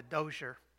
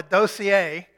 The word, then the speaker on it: dossier: Wouldn't you have a file, or as the French say, a dossier? In East Tennessee, we call it a dozier, because that's how it's spelled. But the French call it a dossier.